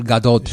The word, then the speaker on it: Gadot: I guess it's the, you know, she's Israeli, so they pronounce the T. Apparently, when you say Gal Gadot. – Gadot. – So she's going to be Gal Gadot.